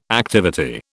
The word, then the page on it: activity: British English